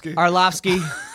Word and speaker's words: Arlovski: All right, final thing before we get to our first caller, Brendan Schaub versus Andre Arlovski. Did I just butcher that? – Arlovski.